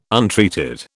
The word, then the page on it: untreated: British English